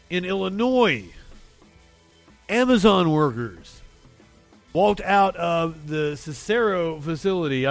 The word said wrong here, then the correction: Cicero: I think I'm saying that right. – Amazon facility in Cicero, Illinois. – Cicero. – Walked out this morning. The group. – I said it like I was from Alabama.